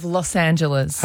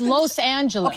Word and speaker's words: Los Angeles: a little debate about the proper pronunciation of Los Angeles.